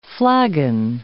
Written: flagon

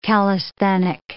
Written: calisthenic

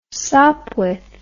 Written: Sopwith

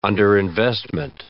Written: underinvestment

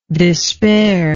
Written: despairer

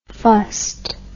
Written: fust

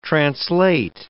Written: translate

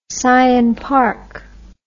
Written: syonpark

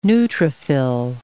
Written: neutrophil

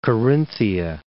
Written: Corinthia

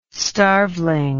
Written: starveling

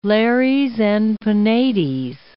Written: lares and penates

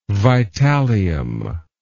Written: Vitallium

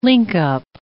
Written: linkup

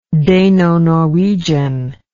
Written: Dano-Norwegian

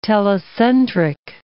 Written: telocentric

